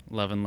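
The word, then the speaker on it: Loven: Lovin', lovin', lovin'. – And that's L-O-V-E-N.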